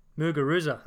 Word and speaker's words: Muguruza: The favourite, I don't know how to pronounce her name, is it? – Muguruza. – Muguruza, I think.